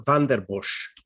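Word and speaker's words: Vanden Bossche: Van de Bosch? – Geert van der Bosch. – Thank you. No wonder I didn't say it right.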